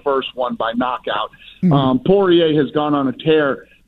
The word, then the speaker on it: Poirier: Is it Poirier? – Poirier. – Sorry about that.